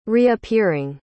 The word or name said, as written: reappearing